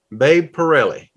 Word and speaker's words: Babe Parilli: Babe Parilli.